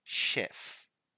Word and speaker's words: chef: So this word is actually pronounced chef. Chef.